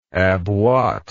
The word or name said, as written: abwatt